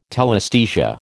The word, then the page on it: telesthesia: American English